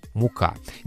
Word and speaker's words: мука: Мука. Не путать со словом «мука». Есть еще одно слово, мы пишем его также, но ударение там другое. Здесь у нас мука, ударение на А.